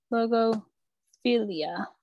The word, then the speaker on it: logophilia: Logophilia. – Is it lingo or logo? – Logo. It's logo. I say lingo the second time, but it's logo. – Logophilia.